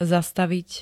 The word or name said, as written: zastavit